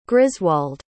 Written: griswald

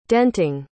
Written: denting

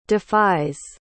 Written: defies

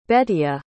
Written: Betia

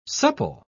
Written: supple

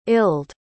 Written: ilde

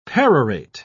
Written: perorate

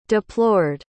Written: deplored

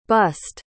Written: bused

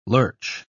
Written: lurch

